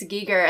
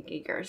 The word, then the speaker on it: Giger: I've been saying Geiger my whole life, and then all of a sudden, everyone's like, it's Giger, and then I actually watched, like, his documentary, and everyone's saying Giger, so. – Okay. – We've been saying it wrong.